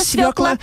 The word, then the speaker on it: свёкла: И теперь, благодаря ему, и мы, и все ребята правильно ударение в слове «свекла» ставить будем. – Да, давай скорее повторять нашу подсказку-запоминалку. – Посадила Фёкла свёклу, выросла у Фёклы свёкла, и гордится Фёкла урожаем свёклы.